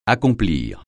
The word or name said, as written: accomplir